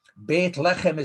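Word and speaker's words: Beit Lechem (בית לחם): Beit Lechem is how you pronounce Bethlehem, and Beit Lechem is the house of bread.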